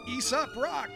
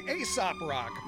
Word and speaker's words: Aesop Rock: Or as Americans say, Aesop Rock. My wife's British and she turned me on to him, so I'm going with Aesop. Why, it's a diphthong on the cultural divide.